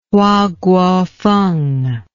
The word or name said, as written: Hua Guofeng